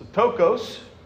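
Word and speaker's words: ptochos (πτωχός): Uh, it's ptochos, P is silent, and then you the CH in Greek is a k, right? So ptochos.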